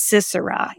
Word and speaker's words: Sisera: Do you guys know how to pronounce that one? Maybe Sisera. It's Sisera. Yes, thank you.